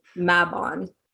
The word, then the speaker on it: Mabon: Mabon is what it is. Mabon or Mabon.